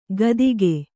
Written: ಕಾತಿಕೆ

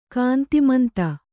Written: ಕಾಂತಿಮಂತ

